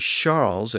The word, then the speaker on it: Charles: And today it's pronounced as an SH sound in French. So Charles is actually Charles in modern French.